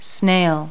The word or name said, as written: snail